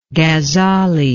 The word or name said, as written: ghazzali